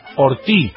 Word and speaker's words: Ortiz: Sí, Ortiz, porque no se pronuncia esa Z final.